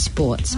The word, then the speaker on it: Spawts: I think it's actually pronounced Sports, but it's Spout, S-P-A-W-T-S. It's like Sports.